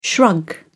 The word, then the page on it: shrug: American English